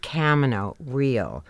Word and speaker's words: Camino Real: The name of the play, Camino Real by Tennessee Williams, is indeed pronounced Camino Real. Unlike the Spanish Camino Real, which means royal road, Camino Real is where the royal road meets the real road.